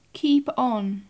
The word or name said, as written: keep on